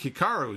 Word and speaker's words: Hikaru: Is it Hikaru, like Pikachu, or is it Hikaru? I think Hikaru is the way to say it.